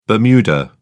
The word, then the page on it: bermuda: British English